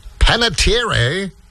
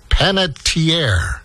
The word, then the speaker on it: Panettiere: On a beautiful Monday morning and some sad news in the entertainment world, actress Hayden Panettiere, I think I mispronounced that, Panettiere, that's it, Hayden Panettiere, passing away over the weekend, 36 years of age.